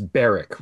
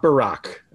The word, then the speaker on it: Barrack: And right up front, we should say, it's Barrack, right? It's Tom Barrack. It rhymes with Bernie Kerik. It rhymes with Oil Derrick. It's not Barack, okay? That's our guy. That's the president. We don't dignify this criminal with pronouncing his name that way.